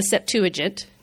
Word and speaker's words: Septuagint: This document, and I'm going to pronounce it like Americans, is the Septuagint.